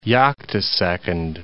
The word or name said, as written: yoctosecond